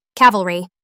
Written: cavalry

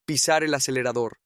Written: pisar el acelerador

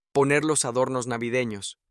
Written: poner los adornos navidenos

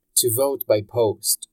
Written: to vote by post

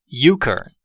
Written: euchra

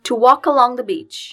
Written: to walk along

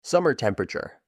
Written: summer temperature

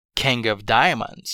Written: king of diamonds

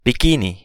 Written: bikini

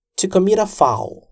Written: to commit a foul

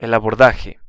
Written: el abordaje

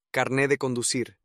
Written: carne de conducir

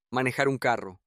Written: manejar un carro

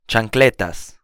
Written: chancletas

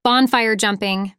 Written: bonfire jumping

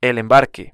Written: el embarque

